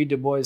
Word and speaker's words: Du Bois: Du Bois said, am I pronouncing him correctly? – Yes. – Du Bois? – Yep. – I think that's the American pronunciation, not the French.